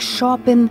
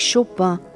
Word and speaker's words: Chopin: Ao gravar os famosos 24 prelúdios do Opus 28 de Frédéric Chopin, pronúncia original polonesa para a versão francesa, Frédéric Chopin,